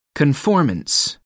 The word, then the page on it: conformance: American English